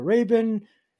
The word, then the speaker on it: Rabin: Rabin. Did I say that right? – Yeah, Rabin.